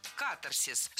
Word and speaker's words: катарсис: Было сказано «катарсис», но это неверно. В этом слове ударение следует ставить на первый слог – «катарсис». Слово имеет древнегреческие корни, восходит к глаголу, означавшему «очищать», и буквально переводится как «очищение». Запоминаем ударение на первом слоге – «катарсис».